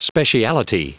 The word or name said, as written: speciality